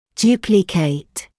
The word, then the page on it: duplicate: [‘d(j)uːplɪkət]; [‘д(й)уːпликэт]